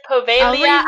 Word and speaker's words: Poveglia: It's a Povelia Island. – It is Povelia Island.